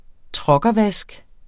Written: truckervask

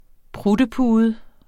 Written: pruttepude

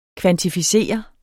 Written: kvantificere

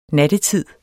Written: NATTETIDEN

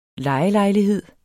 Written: lejelejlighed

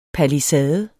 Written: palisade

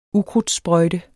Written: ukrudtssprøjte

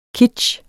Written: kitsch

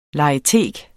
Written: LEGETEK

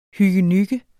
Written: hyggenygge

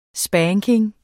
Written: spanking

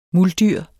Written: MULDYRENES

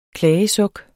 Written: klagesuk

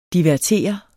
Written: divertere

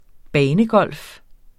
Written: banegolf

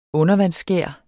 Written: undervandsskær

